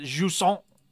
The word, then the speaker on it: Jusant: This is the title is a French term, so we should pronounce it jusson.